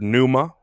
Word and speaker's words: pneuma: In Greek, the word pneuma, which has a silent P at the beginning, can mean breath, spirit, or soul.